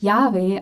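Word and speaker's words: Jahwe: Ähm, Im deutschsprachigen Raum wird dieser Name oft Yahweh ausgesprochen und das ist der Eigenname Gottes.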